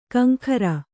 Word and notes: ಕಿಂಕರ: kannada

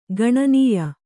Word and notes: ಗಣನೀಯ: gaṇanīya

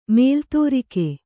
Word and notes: ಮಲೆದುರ್ಗ: male durga

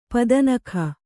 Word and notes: ಪದನಖ: kannada

